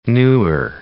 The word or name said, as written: Nuer